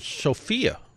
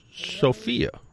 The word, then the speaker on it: Sephia: I think it was the Cephea. – Which I think it's. I think you're supposed to pronounce it Sophia. – Ah. Sephea. – Yeah, but it's spelled Sephia, but I believe they pronounce it Sophia.